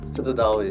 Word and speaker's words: Citadao: Yes, Citadao is the right way to pronounce it.